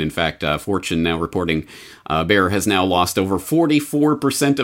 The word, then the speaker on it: Bayer: It's technically pronounced Bayer, but I'm Canadian, so I'll say Bayer.